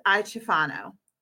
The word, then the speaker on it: Iaciofano: So I would like to introduce Martine Emmons and Beth Iaciofano.